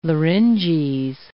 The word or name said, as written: larynges